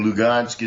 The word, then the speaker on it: Lugansk: Luhansk is in the Ukrainian pronunciation and Lugansk is the Russian pronunciation.